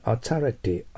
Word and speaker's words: authority: Dr. Oka is an authority on geology となります。 authority と発音しますね。 authority はアと発音してもいい。アーというのは曖昧なアーですよ。 authority でもいいと思います。 O と言ってもいいと思いますよ。